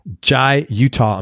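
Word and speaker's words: Jai Uttal: including groups ranging from the Hindustani-influenced Jai Atal. Do I pronounce that right? – Utah. – What is it? – Utah. – Jai Utah.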